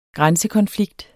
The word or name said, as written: grænsekonflikt